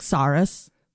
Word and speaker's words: Psarras: The last name, P-S-A-R-R-A-S, probably just like Saurus, silent P.